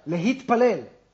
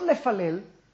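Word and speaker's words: Lehitpalel (להתפלל): Lehitpalel. It's hitpael. It's passive. – Reflexive. – It's not lefalel. It's lehitpalel. In other words, if you really want to translate that concept, I'm going to activate myself into prayer.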